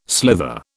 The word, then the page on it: slither: British English